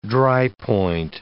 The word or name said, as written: drypoint